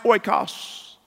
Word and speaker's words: oikos: Now quickly, that word for house in the Greek is pronounced oikos.